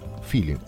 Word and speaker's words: filin: die Anfänge des Feeling. Die Feeling-Bewegung, also Feeling, F-I-L-I-N, also wie man Feeling mehr oder weniger auf Spanisch aussprechen würde.